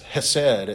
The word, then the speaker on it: hesed: It's hesed.